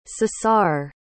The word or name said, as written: ceasar